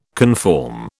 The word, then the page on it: conform: British English